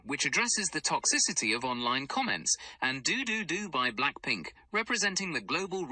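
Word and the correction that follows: DDU-DU DDU-DU: Do-do-do-do is how you pronounce it, to be precise.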